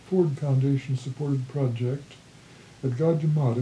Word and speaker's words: Gajah Mada: One thing I kind of wanted to ask you about was the Gajah Mada project. Is that how you pronounce it? – Yes.